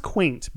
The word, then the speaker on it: queynte: It's pronounced quaint, but it was spelled at that time Q-U-E-Y-N-T-E.